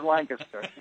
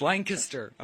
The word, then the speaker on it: Lancaster: Is it Lancaster? Ron Syrak, welcome to the show. – Spoken like a true native. Yes, it is. It is Lancaster. – Yeah, it's spelled like Lancaster, guys, but it's Lancaster.